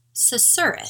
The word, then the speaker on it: susurrus: And this week's word is, I hope I pronounced this correctly, susurrus. Susurrus.